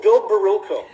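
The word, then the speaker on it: Bill Barilko: Is that how you say his name? He went missing. – Oh, Bill Barrillo. Yeah.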